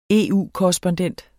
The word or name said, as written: EU-korrespondent